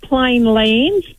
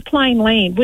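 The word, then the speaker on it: Plainland: I always call it plain land, but it's actually plain land, which doesn't sound right. – There's no S. – Yeah, there isn't an S, but we do that.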